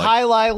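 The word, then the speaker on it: jai alai: He had a beer called, I don't know how to pronounce it correctly, Hialai or Jialai, the old game with the hooks that people used to play. – It's called Hialai, Luke.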